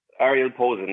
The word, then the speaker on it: Ariel Posen: Ariel Posen? Is that how you say his name? – Ariel Posen, yeah.